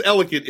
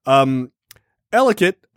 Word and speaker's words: Ellicott: It's pronounced Ellicott in Maryland. I don't think everyone else pronounces it that way.